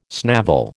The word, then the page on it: snavel: American English